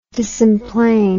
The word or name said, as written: disemplane